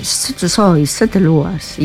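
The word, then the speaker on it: Sete Sóis Sete Luas: Festival Sete Sois Sete Lúas. – No, tú tienes que cerrar un poquito. Sete Sois Sete Lúas y ya está.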